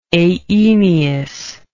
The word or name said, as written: aeneous